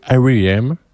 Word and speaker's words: R.E.M.: Mais avant ça, on va écouter un morceau de musique de R.E.M. Je prononce bien, t'as vu? Oui, R.E.M.